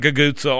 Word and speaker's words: cucuzza: C-U-C-U-Z-Z-A is how you spell it. I do my best to pronounce it. Gagutza. Tony, is that how you pronounce it? Gagutza? Tony, my friend who knows these pronunciations because his grandmother knew them all.